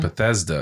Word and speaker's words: Bethesda: I think it was Batista. – Batista? – Batista? Batista? – Bethesda. – Bethesda. – Yeah, I said it wrong. Bethesda. And you said Batista.